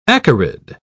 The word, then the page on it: acarid: British English